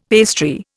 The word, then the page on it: pastry: Indian